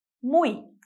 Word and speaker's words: muy: Para finalizar, o último som que você vai ver aqui é quando o Y está no final de uma palavra. Nesse caso, ele sempre vai ter o som de I, sempre. Por exemplo, muy, que significa muito. Só pode ter essa pronúncia. Muy, muy. E a palavra rei, que é rei.